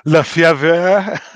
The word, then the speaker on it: Lefevre: I can never say his name right. Dan Lefevre. – Lefevre. – Lefevre. Dan Lefevre. Yeah. – Yeah. It's all French spelling.